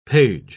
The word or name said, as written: page